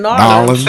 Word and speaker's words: New Orleans: That's how you say it. In New Orleans.